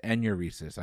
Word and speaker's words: enuresis: A common problem during childhood, nocturnal uronesis, is a medical term better known as what? – Bedwetting. – That is correct, and I mispronounced that. It's enuresis. I apologize. You got it anyway. A common site for piercings, the auricle. A-U-R-I-C-L-E is a cartilaginous region known for what part of the body? – I'm just going to say the earlobe.